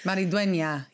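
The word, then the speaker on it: Maridueña: X-O-L-O. – Okay. – Yes. – Meridueña. Yeah, it is. That is how you say his last name.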